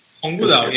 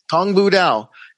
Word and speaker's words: Tong Bu Dao: I wouldn't call it a business statement, but I was super interested because you have something called, and I'm going to mispronounce this. You can help me out here. Tong Bu Dao. – Tong Bu Dao, yes. – Tong Bu Dao. The way of synchronicity. Is that correct, my friend? – Yes, that is what they teach nowadays.